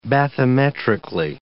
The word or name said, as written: bathymetrically